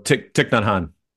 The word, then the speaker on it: Thich Nhat Hanh: That sentence reminds me of the famous quote from, how do you pronounce it? Thich Nhat Hanh? – Thich Nhat Hanh.